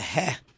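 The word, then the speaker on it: heh (ה): The pronunciation is like breathing. It's just a heh.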